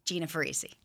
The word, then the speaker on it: Gina Farese: Gina Farese.